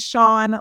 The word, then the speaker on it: Chan: So yeah, if you're listening and you are wanting to check out Sean Osina Music, my name is spelled differently. Let me spell it for you. It's C-H-A-N is Sean, last name Osina, O-C-C-E-N-A, and then music.